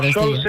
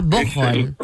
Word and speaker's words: Bocholt: Bocholt se escribe en español y Bocholt. – Ah, Bocholt. – La pronunciación alemana, ¿no?